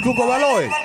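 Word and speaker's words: Cuco Valoy: It's Coco Valo's... Bale El Cal. Listen, it's like I can't pronounce this shit. – Some Spanish shit? – Okay, you can pronounce it. – Oh, shit, it's Cuco Baloy.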